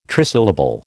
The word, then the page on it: trisyllable: American English